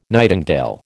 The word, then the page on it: nightingale: American English